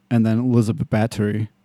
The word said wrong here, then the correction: Bathory: whatever how they say it. Bathory.